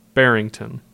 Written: Barrington

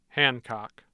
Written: hancock

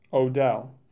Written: Odell

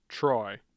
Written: Troy

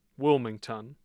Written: Wilmington

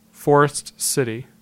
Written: Forest City

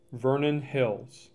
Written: Vernon Hills

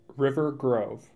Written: River Grove

